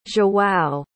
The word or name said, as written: joao